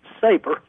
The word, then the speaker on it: Sabre: And correct me if I'm saying it wrong, but I believe his name was S-A-B-R-E? – Yes, but it's sort of a play on the spelling, and it's pronounced Saber.